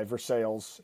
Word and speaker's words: Versailles: Treaty of Versailles, Versailles is how you pronounce it.